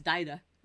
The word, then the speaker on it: Dagda: So the Dagda, which can also be pronounced Dida, it sort of depends on what your preference is there.